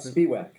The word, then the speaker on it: Spiewak: Just before, how do we pronounce Spiewak, incidentally? Is that right, or is it different? – Spiewak. – Spiewak, okay. Let's get that right.